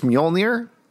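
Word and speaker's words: Mjölnir: Uh, sorry, could you pronounce the name of his hammer just one more time? Mjolnir. Mm, okay, I think it's Mjolnir.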